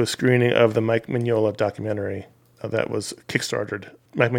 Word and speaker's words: Mignola: I didn't know anything about Mike Mignola, really.